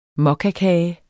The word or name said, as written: mokkakage